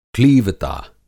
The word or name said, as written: क्लीवता